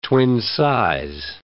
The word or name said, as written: twin-size